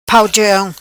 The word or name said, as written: Pau Chong